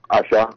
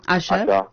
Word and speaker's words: Asa: My first one is do you pronounce it Asha? Aza? ASA? – Asha. – Asha.